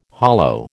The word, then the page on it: hollo: American English